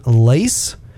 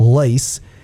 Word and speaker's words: Lacz: And we have a fantastic guest speaker, Kevin Lace. It's spelled L-A-C-Z, but pronounced Lace.